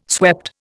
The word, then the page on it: swept: Indian